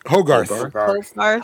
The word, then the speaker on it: Hogarth: Hogarth.